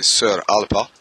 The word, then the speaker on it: Saor Alba: D'ailleurs, la vraie prononciation, c'est Sir Alpa.